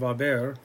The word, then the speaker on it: haber: The personal recommendation, the formula would be, take the present tense of haber, A, as, a, amos, on, right? Remember the H is silent.